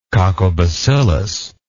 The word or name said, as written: coccobacillus